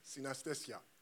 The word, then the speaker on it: synesthesia: They call it anesthesia, right? How did they call it? Sina what? Maybe I'm not hearing it very well when I was hearing it there. Sina what? Synesthesia.